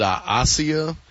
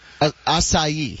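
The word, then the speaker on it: açaí: I was calling it a Asia. – Acai. – Oh, yeah, okay. – Yeah, everybody in L.A. knows how to pronounce acai. – Yeah, in Cleveland, not so much. – A-C-A-I.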